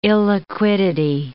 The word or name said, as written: illiquidity